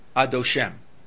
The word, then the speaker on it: Adoshem: That means the accent is on the later syllable. In other words, it's on the last syllable. It's not Adoshem, but rather it's Adoshem.